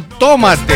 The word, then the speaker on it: tómate: Bueno, ellos le ponen el acento en la O, en la primera O. Es chileno, tómate, ¿eh? Y nosotros acá en Argentina decimos tomate, ¿no?